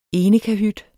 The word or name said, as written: enkeltkahyt